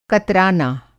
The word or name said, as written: कतराना